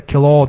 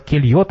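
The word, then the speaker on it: Queloz: Ну, французский, э, фамилия читается Кило, там, например. А там, на самом деле, Келье, там что-то еще было написано. Я поправил на Кило, потому что правильно читать по-французски.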